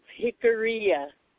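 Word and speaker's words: Jicarilla: I have a question, and it's pronounced Jicaria.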